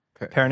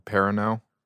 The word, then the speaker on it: Perrineau: Perrineau. Harold Perrineau.